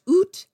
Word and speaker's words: ut: The ut was originally an adverb pronounced oot that meant out.